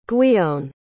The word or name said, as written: gwion